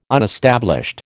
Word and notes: unestablished: American English

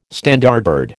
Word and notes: Standardbred: American English

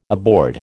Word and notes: aboard: American English

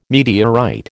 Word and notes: meteorite: American English

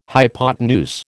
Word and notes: hypotenuse: American English

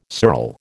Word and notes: sural: American English

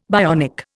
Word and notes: bionic: Indian

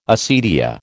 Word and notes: acedia: American English

